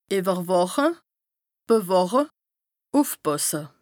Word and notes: ìwwerwàche, bewàche, ùfpàsse: Bas-Rhin; Strasbourg

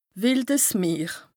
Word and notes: wìldes Meer: Bas Rhin; Strasbourg

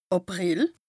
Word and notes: Àprìl: Bas-Rhin; Strasbourg

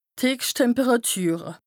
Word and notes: d' Heechschttemperàtüre: Bas-Rhin; Strasbourg

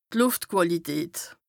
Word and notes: D' Lùftquàlität: Bas-Rhin; Strasbourg

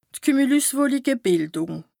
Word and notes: d' Kümülüswolike-Bìldùng: Bas-Rhin; Strasbourg

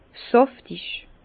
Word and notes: sàftig: Bas-Rhin; Herrlisheim

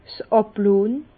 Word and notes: 's Àblohnn: Bas-Rhin; Herrlisheim

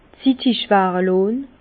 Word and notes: zittig ware lonn: Bas-Rhin; Herrlisheim

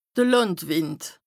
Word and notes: de Làndwìnd: Bas-Rhin; Strasbourg

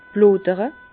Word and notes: d' Blodere: Bas-Rhin; Herrlisheim

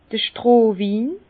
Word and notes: d'r Strohwin: Bas-Rhin; Herrlisheim

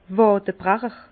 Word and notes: Wàdebrecher: Bas Rhin; Herrlisheim

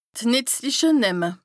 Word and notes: D' nìtzliche Nämme: Bas-Rhin; Strasbourg

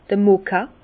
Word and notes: d'r Moka: Bas Rhin; Herrlisheim

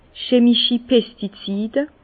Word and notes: chemischi Pestizide: Bas Rhin; Herrlisheim

